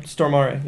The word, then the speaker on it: Stormare: Is that really how you pronounce it? Stormare? – Stormare, yeah.